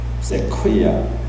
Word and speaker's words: Sequoia: 세케이아 내셔널 파크라는 세케이아라고 하나요? National Park. Sequoia. 라고 이 발음을 하려고 제가 몇 번을 연습했는데 Sequoia National Park.